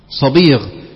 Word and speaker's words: صبيغ: صبيغ على وزن عظيم،